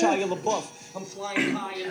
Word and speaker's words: Shia LaBeouf: And it's pronounced Shia LaBeouf.